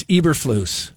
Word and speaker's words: Eberflus: Eberflus. – It's Eberflus. – Eberflus. Eberflus. – But I did post something. You know, it's hard to imagine a statue outside of Soldier Field with, you know, the Eberflus statue.